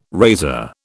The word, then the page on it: razor: British English